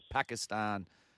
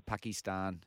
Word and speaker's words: Pakistan: And then you've got the Pakistan, or Pakistan, however you want to pronounce it, openers Rizwan and Babar Azam in form as well.